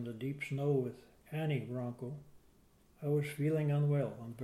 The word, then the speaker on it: Wronko: W-R-O-N-K-O. Ronco, yeah. It's a W-R. Kind of hard to say Ronco. You want to say W-O-R, but It's W-R.